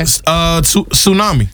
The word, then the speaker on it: tsunami: This has a silent letter in it. – Okay. – Tsunami. – Tsunami. – Tsunami. – Tsunami. Sure does. – Now, some people might say tsunami. – Tsunami. – Tsunami. Yeah. Somebody might say that. No. They'd be wrong.